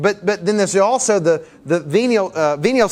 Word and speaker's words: venial: Venial? Did I say that right? V-E-N-I-A-L. You ever heard that word? Venial, say that right? Okay, good.